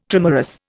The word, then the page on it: trimerous: Indian